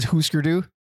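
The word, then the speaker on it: Hüsker Dü: And by the way, I got an email asking you to pronounce it Husker Du. It is Husker Du. – Husker Du. – Clearly, he does not read umlauts.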